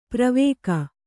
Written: ಪ್ರವೇಕ

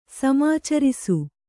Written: ಸಮಾಚರಿಸು

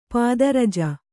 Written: ಪದರಜ

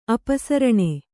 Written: ಅಪಸರಣ

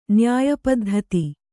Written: ನ್ಯಾಯಪದ್ಧತಿ

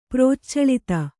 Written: ಪ್ರಚಳಿತ

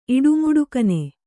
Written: ಇಡುಮುಡುಕನೆ

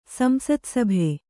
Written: ಸಂಸತ್ಸಭೆ

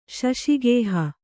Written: ಶಶಿಗೇಹ

